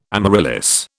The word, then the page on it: amaryllis: British English